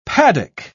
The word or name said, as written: paddock